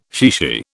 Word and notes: chichi: British English

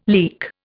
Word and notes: leak: Indian

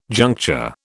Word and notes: juncture: British English

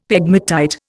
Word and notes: pegmatite: Indian

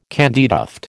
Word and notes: candytuft: American English

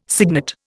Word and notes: cygnet: Indian

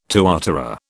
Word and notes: tuatara: British English

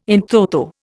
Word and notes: in toto: Indian

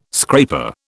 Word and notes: scraper: British English

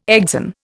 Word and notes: exon: Indian

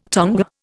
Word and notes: tonga: Indian

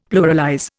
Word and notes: pluralize: Indian

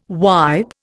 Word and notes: wipe: Indian

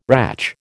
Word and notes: ratch: American English

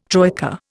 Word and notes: troika: Indian

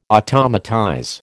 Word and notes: automatize: American English

